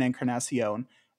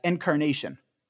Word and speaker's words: Encarnacion: I remember him pronouncing the name Edwin Encarnacion as Encarnation.